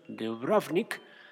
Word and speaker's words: Dubrovnik: Los historiadores eh, refieren que eh, pues lo que en su momento fue el poblado de Ragusa, que hoy llamado Dubrovnik, chécate la pronunciación, Dubrovnik,